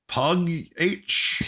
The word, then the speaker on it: Pugh: I have Florence Pug H. – Pugh. – It's pronounced Pug H. – It's Pugh.